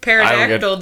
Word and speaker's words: pterodactyl: And it pronunciates with a P. Pterodactyl doesn't. – Pterodactyl? pterodactyl. – Yeah, but you don't say the P. You don't say pterodactyl. You do say the P in pencil.